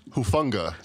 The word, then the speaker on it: Hufanga: How do you pronounce his name? – Hufanga. Talona Hufanga.